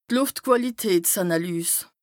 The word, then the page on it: d' Lùftquàlitätsànàlys: Bas-Rhin; Strasbourg